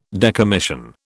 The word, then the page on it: decommission: British English